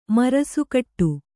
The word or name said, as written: ಮರಸುಕಟ್ಟು